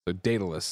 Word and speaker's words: Daedalus: At this point, Google's pronunciation of Daedalus is, Daedalus.